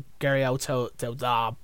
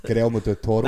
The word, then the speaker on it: Guillermo del Toro: It won't be Silent Hill, but it'd be the same sort of game, but with Kojima and Guillermo del Toro. Butchered his name. How'd you say it? – Guillermo del Toro. – That's the one.